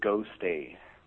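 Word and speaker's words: Gostay: My gamer tag is Go Stay.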